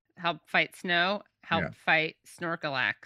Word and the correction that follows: Snorlax: Did I get the name right? – Snorlax. – Snorlax, yeah.